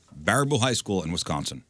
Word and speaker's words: Baraboo: Students at Barbaro High School. I'm sorry, I'm saying that wrong. Is it Baraboo High School? B-A-R-A-B-O-O, Baraboo.